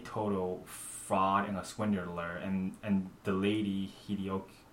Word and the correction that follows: Hideko: How do you pronounce Hideko? – Yeah, Hideko.